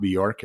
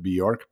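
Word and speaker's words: Björk: It's not Bjork. Everybody pronounces it Bjork, but it's actually Bjork.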